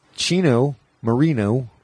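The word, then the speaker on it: Chino Moreno: Chino Marino, which is funny to say because it rhymes.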